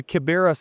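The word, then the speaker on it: Kibera: Another thing that I noticed that I was reading about, and I hope I say it right, the Kiberia Slums Campaign. Did I say that correctly, Kiberia? – Well, close enough. It's the Kiberia slum.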